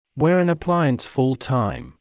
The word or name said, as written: wear an appliance full-time